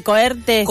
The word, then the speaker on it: cohorte: Nos vamos a ir hacia la academia, hacia la Facultad de Filosofía y Letras, porque se va a estar llevando adelante una coerte sobre la maestría en estudios feministas. Y para hablar en profundidad de, primero, si lo dije bien, si se dice cohorte. – Cohorte. – Cohorte con doble. Cohorte.